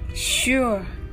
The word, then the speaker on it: sure: 発音は「シュ」を言った後は自然と口を開けると「あ」になるような発音にしてください。それでは一緒に練習してみましょう。 sure, sure この sure 日本語だと漢字で手に話と書く手で言葉を伝える手話がありますね。